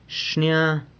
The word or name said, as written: xnia